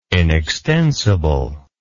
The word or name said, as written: inextensible